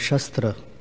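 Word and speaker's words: śastra: There is a word, shastra. And there's also another word without the long A called shastra. In Sanskrit, shastra means scripture, and shastra means weapon. Just one makes a difference.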